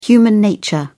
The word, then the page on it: human nature: British English